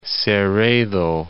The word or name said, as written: Cerredo